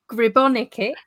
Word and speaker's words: Gribonika: Today I have got Jenny Gribonika.